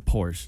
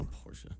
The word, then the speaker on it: Porsche: And by the way, it's a Porsche. It's not a Porsche. – Porsche. That's the right European way to say it.